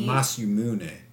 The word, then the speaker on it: Masamune: And he was 357 years old when Mazumun? – Excuse me? – When he was... – Let me see. Is it the name of the sword? – Masamune. Masamune. – I believe is how you say it.